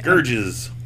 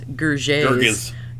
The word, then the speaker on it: Gerges: Gerges. – It's G-E-R-G-E-S. Gerges. Gerges.